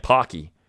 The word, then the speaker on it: Psaki: The White House has been mostly silent on the issue, often sending Press Secretary Jen Psaki into the briefing room I'm sorry, I said her name wrong. Jen Psaki, because you forget the S if you're Joe Biden.